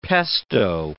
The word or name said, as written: pesto